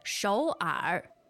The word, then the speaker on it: 首尔: 首尔。首尔. – Shou'er. What are the tones there? – Two-third tones.